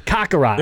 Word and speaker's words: Kakarot: Mickey just eye-rolled because you mispronounced Kakarot. – Yeah, Kakarot. – Yeah, he said it right.